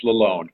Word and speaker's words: Lalonde: It's Lalonde, but I get a lot of different pronunciations. It's a French name, so.